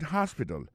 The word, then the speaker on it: hospital: She likes to visit hospital. Li reme al visiter l'hôpital. L'hôpital c'est comme ça on dit ça en anglais hospital. Alors accent tombe sur premier syllabe là, il y a trois syllabes.